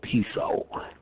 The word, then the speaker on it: Piso: Yes, that's the P-I-S-O, Piso. Piso is pronounced Piso, family of Rome.